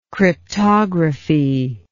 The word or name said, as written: cryptography